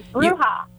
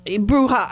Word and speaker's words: Bruja: Bruja. – Bruja. I knew it was Bruja, but, you know, they... I get people who then they'll pronounce it with the J, and I get so nervous.